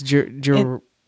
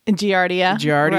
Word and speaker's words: giardia: Giardia. – Giardia.